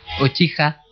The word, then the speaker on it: Uchiha: Uchiha, ¿lo he dicho bien? – Uchiha.